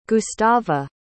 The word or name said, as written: gustava